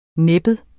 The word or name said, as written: næbbet